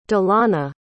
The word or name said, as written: dilana